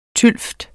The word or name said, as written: tylvt